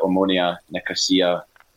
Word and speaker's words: Omonia Nicosia: But I did notice that, and I've been meaning to look up their gear, the Omonia Nicosia, that's the correct pronunciation, because they've got the shamrock.